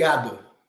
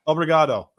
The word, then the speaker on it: Obrigado: How do you say thank you in Brazilian? In Portuguese? – Obrigado. – Obrigado. That's right.